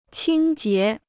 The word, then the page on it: 清洁: qīng jié